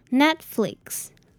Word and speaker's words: Netflix: 这里的 e 我们要发 e 的音，那这里的 t 其实我们不会把它念出来，就是我们的舌头会顶到上面，我们就停住，念作 net net。那后面的 i 呢，则是发 e Netflix Netflix。